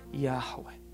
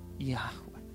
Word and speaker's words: Yahweh: A pronúncia disso, ninguém sabe direito qual é a pronúncia, mas a pronúncia é algo como Yahweh. Yahweh.